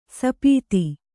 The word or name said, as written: ಸುಪಥ